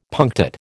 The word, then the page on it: punctate: American English